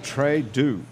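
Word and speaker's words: Très Doux: T-R-E-S. Second word, D-O-U-X. – Oh, so it's like... – Very sweet. – D-E-A-U-X? – D-O-U-X. – So Trudeau, actually, pronounced.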